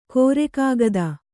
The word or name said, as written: ಕೋರೆಕಾಗದ